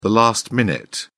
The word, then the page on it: last-minute: British English